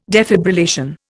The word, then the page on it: defibrillation: Indian